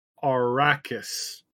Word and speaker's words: Arrakis: It's pronounced Arrakis. That is the planet Dune, in case you were wondering.